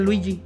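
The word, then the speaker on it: Luis G: No mames, güey, de veras, me lo juro eh, te lo juro que se llama, se llama Luis espacio G, pero lo pronuncia Luigi.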